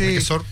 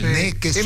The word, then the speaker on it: Nequesor: Nequesor se pronuncia, ¿verdad? – Sí. Nequesor. Nequesor. – Perdón, Nequesor. Por eso se escribe con cepo, entonces dice. Sí. Nequesor. – Es medio francés el apellido, por eso termina así.